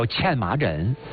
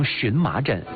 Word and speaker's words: 荨麻疹: 这最常见到的一个皮肤病，把它叫欠麻疹，今天大家也把它读成荨麻疹，其实就是老百姓过去所说的风疹疙瘩。